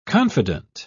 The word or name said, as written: confident